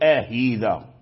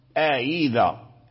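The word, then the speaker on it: أئذا: أنك ترد الهمزة المسهلة هاء تقول آهيذا آهيذا سماهينا سماهينا، لا هذا لحن لا تجوز القراءة به، وإنما تنطق بهمزة كما ذكرنا ملينة مسهلة فقط، لا يظهر في لفظك وفي نطقك وفي صوتك لا يظهر الهاء، أي أن الهاء لا يكون له أثار في النطق الذي يسهل.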